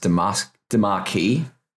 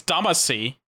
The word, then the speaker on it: Damacy: That's how I was going to pronounce it, but I have heard it pronounced Damarkey. – I'm not really sure. I think it's Damacy.